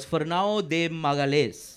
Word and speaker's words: Fernão de Magalhães: So one Portuguese adventurer that was present at the capture of Malacca was Fernão de Magalhães. – Try that again. – What? Did you have a stroke? What? – Yeah. Try it again. – That is the Portuguese pronunciation.